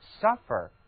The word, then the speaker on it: suffer: suffer， suffer，字母 u 它读作三角的那个啊， suffer，这是一个让人非常痛苦的单词。